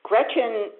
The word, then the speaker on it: Gretchen: Gretchen is a rather unusual name, but it's pronounced like it's spelled, and it's spelled like it's pronounced.